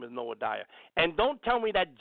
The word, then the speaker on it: Noadiah: Her name is spelled N-O-A-H, like Noah. Noah, and then I-D, let me spell it again, N-O-A-H, like Noah, D-I-A-H, Noadiah. Noadiah, the false prophetess.